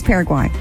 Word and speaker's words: Paraguay: It is Paraguay. – Yeah. – Paraguay. – Not pair of guys, like you said the first time.